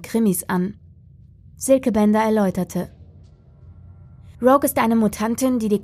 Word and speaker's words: Rogue: Camilla und Rogu? Es wird englisch ausgesprochen. Rogue, korrigierte ihn seine Kollegin.